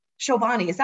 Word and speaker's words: Chobani: Chobani, am I saying that correctly? That's the yogurt company, right? – Yeah.